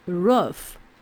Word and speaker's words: rough: 好，我们也是再来做 u 的拼音练习，b a t b a b but but r a 嗯，r a run run b a g bug bug b um 有个 m 的嗯的音哦，b um bump bump r a g rug rug r a f 这边有两个 f，但是我们还是念个 f 的音就可以喽。rough, rough, roof.